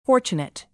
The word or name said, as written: fortunate